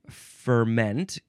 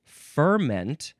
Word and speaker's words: ferment: The second form of ferment can also be pronounced ferment. In fact, that's the first pronunciation, so it's probably more accurate.